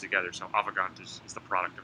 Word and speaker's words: Avegant: Did I say that right? – Yeah, that's right, Avegant.